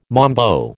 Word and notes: mambo: American English

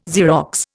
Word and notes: Xerox: Indian

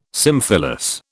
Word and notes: symphyllous: British English

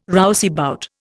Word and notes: rouseabout: Indian

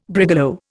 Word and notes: brigalow: Indian

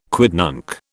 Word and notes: quidnunc: British English

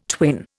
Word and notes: twin: Indian

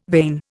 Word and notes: vane: Indian

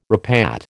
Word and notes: repat: American English

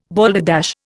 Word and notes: balderdash: Indian

